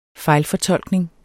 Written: fejlfortolkning

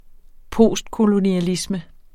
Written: postkolonialisme

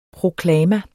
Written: proklama

